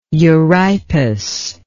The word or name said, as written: euripus